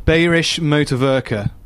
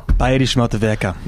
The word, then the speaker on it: Bayerische Motorwerke: Bayerische Motorwerke. – Oh, well. – Bayerische Motorwerke.